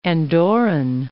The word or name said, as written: Andorran